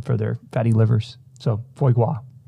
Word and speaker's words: foie gras: I'm probably mispronouncing that. – It sounds right. – I'm looking at the pronunciation. Is it G R A S? – Mm-hmm. Yeah, I That's think gras. you got it.